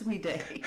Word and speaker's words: Weede: Now, is it pronounced Weed or Weede? – It's Weede. Like Weede.